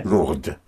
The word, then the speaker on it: Lourdes: L-O-U-R-D-E. – D-E-S, right. – D-E-S. – Oh, yes, yeah. Lourdes. But you were right with the D-E because you heard the sound of the D. Yes, mm-hmm. Lourdes. So how would you spell Lourdes? – L-O-U-R. – L-O-U-R-D.